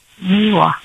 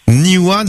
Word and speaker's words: Niwa: Niwa d'Alpha Blondie, vous et moi? – Oui. – Vous connaissez comment elle écrit, Marie? – Oui. N-I-W-A. – N-I-W-A, comme ça se prononce, quoi.